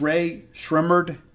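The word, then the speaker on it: Rae Sremmurd: Now, I have been given the pronunciation guide on this, and it's Ray Shremmerd, and it's Eardrummers backwards. Like for years, I thought it was Ray Shremmerd, and I was like, I didn't know what it all meant, but it's Ray Shremmerd, and it's Eardrummers backwards.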